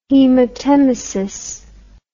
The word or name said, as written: hematemesis